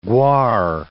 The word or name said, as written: guar